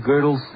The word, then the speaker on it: Gödel: That's spelled G-O-D-E-L. And it's pronounced Gödel because of the umlaut over the O.